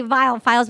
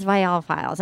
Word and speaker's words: Viall: I always wanted to say Viall Files, but that's not what it is. It's Viall Files.